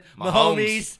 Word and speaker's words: Mahomes: Mahomes. – Yeah. Mahomes' ass. Mahomes. And they're going to march into New England. They're going to beat fucking Brady's ass, They're going to beat Mahomes' ass.